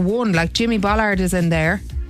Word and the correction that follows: Bullard: Did I say that right? Bullard? – No, almost, yeah. Bullard, yeah. He's Bullard.